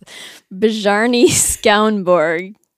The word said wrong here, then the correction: Bjarne: That's probably Bjarne. Like Bjorn.